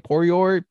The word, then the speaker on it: Poirier: Can Charles Oliveira stop Dustin how do you say his name? Poirier? – You had it right the second time.